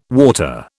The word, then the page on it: water: British English